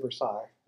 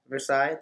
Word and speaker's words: Versailles: Treaty of Versailles. – Versailles.